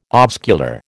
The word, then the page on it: oscular: American English